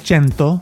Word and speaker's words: cento: Chintani. – Oh, close. Cento. – Cento. – 36. – 36? – 36. – 36. – That's 36. 136. – What's the first word? – Cento.